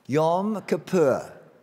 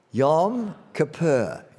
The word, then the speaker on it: Yom Kippur: Yom Kippur. Again, Yom Kippur.